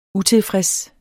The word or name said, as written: utilfreds